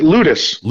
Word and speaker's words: Loutos: Yeah, he pronounces it Ludus. Ludus, that's um, right.